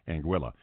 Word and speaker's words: Anguilla: Anguilla. More coming up with Michael Masci in just a moment here at the Big AM 1380 WBEL. – Cuisinart Golf Resort and Spa in Anguilla is delighted to welcome Michael Masci as Director of Entertainment.